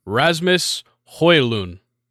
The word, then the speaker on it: Rasmus Højlund: Rasmus Højlund. – So a lot easier. – Yeah, very easy. It's yeah. – You don't pronounce the D, and the J turns to a Y.